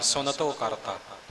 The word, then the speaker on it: सुनतो: सुनतो करता आम तौर पर पाठी बोल जाते ने सुनतो करता, सुनतो करके नहीं बोलना, नन्ने का उच्चारण पूरा करना सुनतो करता।